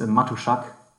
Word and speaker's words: Matuschak: It's pronounced a lot different than it is actually written. It's Matuschak in German.